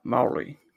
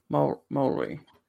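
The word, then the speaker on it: Māori: Pronouncing it, I tried this really hard this week. Maori. I think I did it. Maori. Maori. Yeah, I tried. Rob the R.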